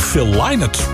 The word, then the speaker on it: Phil Lynott: Gaan wij naar Phil Lynott? Want zo spreek je het uit volgens de familie, hè? Dus nou, die zult wel weten.